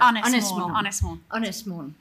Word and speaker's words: Ynys Môn: Ynys Mon.